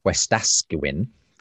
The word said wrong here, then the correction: Wetaskiwin: Canada, Wetaskiwin, um, uh, wants to ask about how we uh, live um, in unity despite profound theological and ethical differences as Christians.